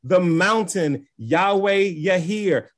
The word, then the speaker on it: Yireh: We say Yireh, but it's Yahir.